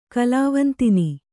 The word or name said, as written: ಕಲಾವಂತಿನಿ